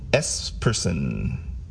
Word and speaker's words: Esperson: His name is Brian Esperson. I think that's how you say it. E-S-P-E-R-S-O-N.